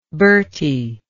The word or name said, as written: Bertie